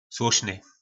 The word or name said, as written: slosny